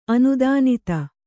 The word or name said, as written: ಅನುದಾನಿತ